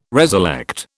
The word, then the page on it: reselect: British English